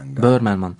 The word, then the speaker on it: Böhmermann: Almanya'da Cumhurbaşkanı Erdoğan'a hakaret içeren şiir nedeniyle komedyen Jan Böhmermann. Doğru telaffuz ettim değil mi? – Böhmermann.